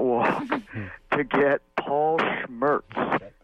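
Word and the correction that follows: Scherz: I'll spell it for you, S-C-H-E-R-Z. I guess I said it wrong. Shirts.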